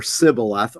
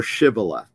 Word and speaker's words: shibboleth: It's the same letter, but this little serif, this little tiny dot, makes a difference between whether it's pronounced s or sibboleth or sh or shibboleth.